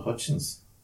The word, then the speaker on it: Hutchence: Yeah, she had she had the uh, Michael Hutchinson. – Michael Hutchence.